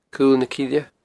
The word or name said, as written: Cul na Cille